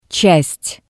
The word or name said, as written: tsast